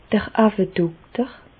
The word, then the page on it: d'r Auwedokter: Bas Rhin; Reichshoffen